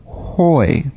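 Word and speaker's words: hoi (οἱ): a rough breather, so it's pronounced hoi.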